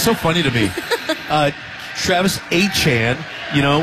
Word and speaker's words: Etienne: It's spelled Etienne, but he says it's now pronounced Etienne, so we're going to go with that.